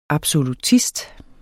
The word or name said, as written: absolutist